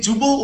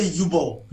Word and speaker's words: Jubal: Am I saying it correctly? – Yes, can say Jubal, or you can say Yubal.